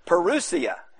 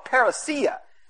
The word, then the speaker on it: parousia: Now, I always get tickled, you know, it's pronounced two different ways depending on where you went to seminary. Where in Southwestern, it was parousia. In other places, they call it parousia.